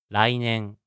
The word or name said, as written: rainen